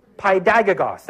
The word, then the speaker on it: paidagogos: Um, Paiodagos. I didn't say that right. Paiodagos. Uh, spell it this way P A I D A G O G O S with a funny O. Right? I can spell it, I just can't say it, which means a lot for me because I normally can't even spell. So, Paidagagos, that's what it is.